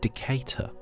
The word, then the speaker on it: Decatur: Ahead of that was Seam with Decatur, as I'm reliably informed it's actually pronounced, as suggested by Julian Lawson, which I really loved that track.